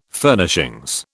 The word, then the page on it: Furnishings: British English